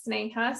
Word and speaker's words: Sneha: Lots of people don't know how to pronounce my name, but that's fine. I always tell my parents, why did they give me such a short but very confusing name to pronounce? But yeah, it's Sneha.